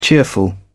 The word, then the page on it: cheerful: British English